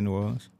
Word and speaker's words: New Orleans: Just say New Orleans.